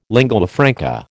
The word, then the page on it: lingua franca: American English